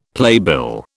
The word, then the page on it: playbill: British English